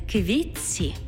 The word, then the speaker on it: квітці: У словах «квітці» та «тітці» звук «т» переходить у м'який подовжений звук «ц» – «квітці» – «тітці».